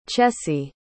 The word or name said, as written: chessie